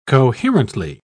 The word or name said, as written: coherently